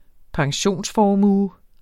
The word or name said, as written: pensionsformue